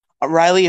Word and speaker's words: Riely: Riley is spelled R-I-E-L-Y, by the way, because a lot of people get that wrong.